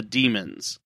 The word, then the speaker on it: Daemons: I always wondered whether to pronounce it Daemon or Daemon, but I guess it's the way they said it in the episode, it was the Daemons.